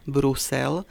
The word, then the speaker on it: Brusel: A to isté je aj pri názve mesta Brusel. Píše sa s jedným S, výslovnosť je s U.